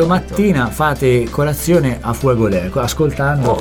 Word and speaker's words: A fuego lento: È una scelta, quindi è A fuego lento di Rosana. – L'ho detto bene? – Sì.